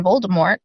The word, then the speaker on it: Voldemort: you know, we all as a society kind of decided that we were going to pronounce the T in Voldemort.